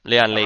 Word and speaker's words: Lian Li: It's the, I might be pronouncing this wrong, so help me out. Is it the Lian Li case? – Yep, Lian Li.